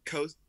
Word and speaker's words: Kosovo: It was like Kosaic. Yeah, I don't know how. Kosic. – No, you're good. Kosovo. Kosovo. – Kosovo. See, yeah.